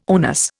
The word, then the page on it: onus: Indian